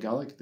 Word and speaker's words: Gaelic: Uh, we call it Gaelic, the Irish call theirs Gaelic.